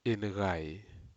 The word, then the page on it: inucai: Aneityum